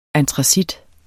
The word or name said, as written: antracit